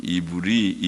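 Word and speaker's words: 이브리 (עברי): 창세기 14장 13절에 최초로 아브라함을 히브리 사람 이렇게 되는데 히브리 원문의 발음은 이브리, 이브리라는 말이고 이브리라는 말은 에벨 같은 단어입니다.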